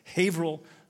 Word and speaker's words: Haverhill: He planted King of Grace Church in 2002, and it's in Haverhill. I think I said that right. Haverhill, Massachusetts. That's the local pronunciation. I haven't been there, but that is how you pronounce it.